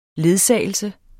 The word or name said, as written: ledsagelse